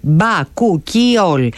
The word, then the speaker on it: Bakuchiol: Η δερμοκαλλυντική σειρά, άκου τώρα όνομα, προφέρεται Bakuchiol Age Active της The Skin Pharmacy.